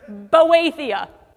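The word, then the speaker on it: Boethia (βοήθεια): This word for help is the word, and I'm going to say it the way they said to pronounce it, Boethia.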